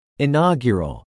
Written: inaugural

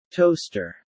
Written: toaster

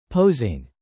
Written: posing

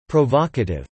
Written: provocative

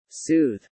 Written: soothe

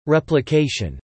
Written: replication

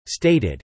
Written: stated

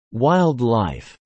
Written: WILD LIFE

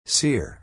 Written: sear

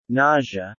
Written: Nausea

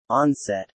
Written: onset

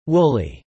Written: woolly

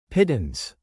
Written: pittance

